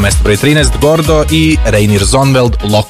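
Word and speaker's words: Reinier Zonneveld: Reinier, kako si izgovara? – Reinier Zonvel.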